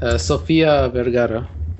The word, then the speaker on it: Sofia Vergara: Sophie Vergara, I believe. Is that how it's pronounced? – Sofia Vergara. – Sofia Vergara, okay.